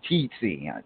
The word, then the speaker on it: Tietsi: I believe that's how it's pronounced. Tietzi. T-I-E-T-S-I. Tietzi the Finisher.